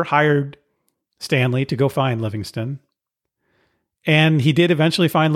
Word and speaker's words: Livingstone: In 1869, the New York Herald sent Stanley to find British explorer and missionary David Livingstone, who hadn't been heard from in 6 years. I've also heard his name pronounced Livingston more commonly, so I'll go with that.